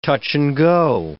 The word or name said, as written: touch-and-go